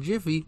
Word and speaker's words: Jif: Is it Jiffy or Jif? – I thought it was Jif. – It's Jif. – Because that's what people use to argue how to pronounce GIF, like the peanut butter. – People think it was Jiffy. When in fact they never used Jiffy in branding. At all.